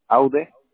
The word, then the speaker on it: Aude: Aude. – Okay. Do you spell that A de Arturo? – U-D-E.